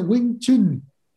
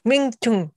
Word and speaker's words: Wing Chun: se, pronuncia Wing Chun. – Wing Chun.